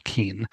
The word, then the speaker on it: Keen: Will Keane's name is spelled K-E-E-N. Mm-hmm. So I guess it's clearly pronounced Will Keane.